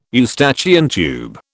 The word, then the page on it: Eustachian tube: British English